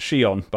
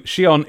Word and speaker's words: Shion: It's pronounced Shion, by the way. Thank you. Shion is an actual given name in Japan, and the Japanese E sound is sometimes romanized as an I for reasons. Thank you.